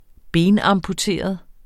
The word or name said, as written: benamputeret